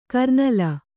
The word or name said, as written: ಕರ್ನಲ್ಲು